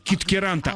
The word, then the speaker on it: Питкяранта: Мой родной город Питкяранта. – Еще раз. – Даже на поле чудес. Город Питкяранта. – Питкяранта.